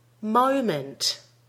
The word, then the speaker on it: moment: In the next word, make sure you're getting an O diphthong vowel in the first syllable. Mo, moment. Many non-native speakers say mo, moment. It should be mo. Ready? Moment.